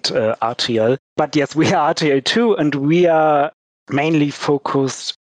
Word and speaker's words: RTL2: Well, RTL2, it's how we pronounce it in English, is one of the private TV stations in Germany.